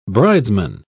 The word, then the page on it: bridesman: British English